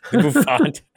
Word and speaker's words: bouffant: Is that how you say it? Their bouffant? – Bouffant.